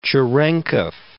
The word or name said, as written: Cherenkov